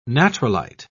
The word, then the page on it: natrolite: British English